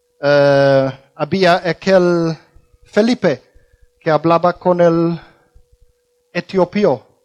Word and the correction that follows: etíope: Etiopía. ¿Cómo se dice? Etíope. Una persona de Etiopía, etíope.